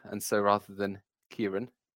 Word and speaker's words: Ciarán: Now, for the record, I do know how to pronounce Ciarán because my brother is called Ciarán.